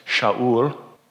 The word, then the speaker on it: Shaul: We know that Paul's Jewish name was Saul, pronounced in the Hebrew as Shaul.